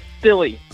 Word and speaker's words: Spille: It's good. It's Spilly. – Spilly. Okay. Okay. Yes. It's like it looks.